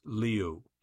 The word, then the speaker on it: Liu: She confirmed it's pronounced Liu, but she said, "I'm good with whatever."